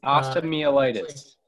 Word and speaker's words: osteomyelitis: osteomyelitis.